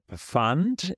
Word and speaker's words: Pfand: There's the Pfand, is that how you say it? – Yes.